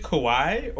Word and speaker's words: kawaii: Is it kawaii or is it umami? – No, it's like it's pronounced like kawaii. – Based in cringe. – Yeah. Like Hawaii.